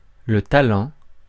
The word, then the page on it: talent: [ta.lɑ̃]; France